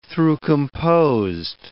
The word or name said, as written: through-composed